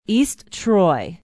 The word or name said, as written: east troy